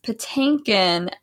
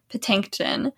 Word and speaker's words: Patinkin: I am obsessed with the fact that you pronounce Patinkin as Patinkin, with an extra T every time you say it the whole time that Gideon's on the show.